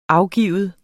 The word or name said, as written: afgive